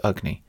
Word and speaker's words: Agni: They say that the short A, so the A that's in Agni but not in Kali, is pronounced like the vowel in the word but. So basically, it's like a almost like a schwa.